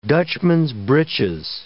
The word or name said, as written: Dutchman's-breeches